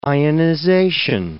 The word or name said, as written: ionization